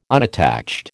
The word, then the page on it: unattached: American English